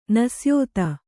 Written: ನಿಸ್ಯೂತ

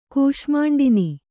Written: ಕೂಷ್ಮಾಂಡಿನಿ

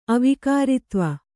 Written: ಅವಿಕಾರಿತ್ವ

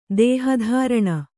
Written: ದೇಹಧಾರಣ

